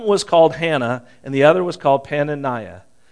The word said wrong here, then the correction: Peninnah: And Penaniah, I'm not probably saying that right. You know, you should always practice these before you do. And I just read, as I read it, I thought, that doesn't sound like Penaniah. Peninnah. I've always said her name wrong.